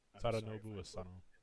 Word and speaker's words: Tadanobu Asano: Tadanobu Asano. – Tadanobu Asano.